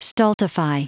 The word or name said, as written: stultify